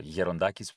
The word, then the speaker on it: Gerontakis: If you Google Aris Gerontakis or Gerontakis, which is the original pronunciation,